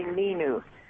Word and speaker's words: Ninu: It's actually Nino.